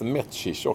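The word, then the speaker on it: Mečíř: Ja, jag fick, tror man fick lära sig att det skulle uttalas Mecir också, det var en sån uttals.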